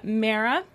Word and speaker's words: Mara: So this week it is, and please forgive me if I'm mispronouncing it, but Mara, kind of like Sarah, but with an M.